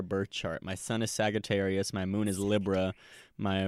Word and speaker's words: Sagittarius: That's nice. The hard G. – Yes. – Sagittarius.